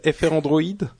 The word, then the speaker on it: Frandroid: Non, Frandroid. Il n'y a pas, comme, j'ai déjà dit, il n'y a pas de prononciation officielle, donc euh, on entend des FR Android, des Frandroid, mais au final, ça revient à la même chose.